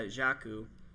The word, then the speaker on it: Jakku: Jakku, I think is how they've been pronouncing? – Yeah, Jakku. – Yeah, Jakku, which is...